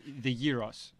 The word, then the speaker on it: gyros: So the gyros, right? – Yeah. – So I like that you say it right. – Well, yeah. – Gyros. – Yeah. – Not gyros. – Not gyro.